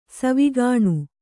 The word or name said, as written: ಸಾವುಗಾಣ್